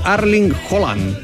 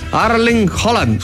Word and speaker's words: Erling Haaland: La pronunciación correcta de el golpeador noruego debe sonar como Erling Haaland. Erling Haaland.